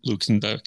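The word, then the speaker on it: Luckenbach: Luckenbeck, Texas. Did I say that right? – Luckenbeck. Yeah.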